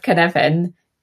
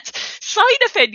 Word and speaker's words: Cynefin: But just really quickly for anyone who's listening to this, Kenevin, the spelling of Kenevin. – Kenevin. So I'll spell it for you. C-Y-N-E-F-I-N.